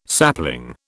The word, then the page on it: sapling: British English